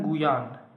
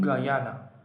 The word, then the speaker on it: Guyana: در فارسی می نویسن گویان در انگلیسی میگن گاینا یا اسم رسمیش Cooperative Republic of Guyana خیلی فکر کردم که بگم گویان یا بگم گایان یا بگم گاینا تصمیم گرفتم اونجوری که مردم خود کشور تلفظ میکنن بگم چون منطقی تره و خودمونم دوست داریم مردم دنیا کشورمون رو همونجوری که ما میگیم تلفظ کنن برای همین از این به بعد میگیم گایانا